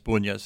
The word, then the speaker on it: Bunjes: Kirsten, who spoke French, came upon this former major, Bunyas, Hermann Bunyas, B-U-N-J-E-S, Bunyas, that's how they pronounce it.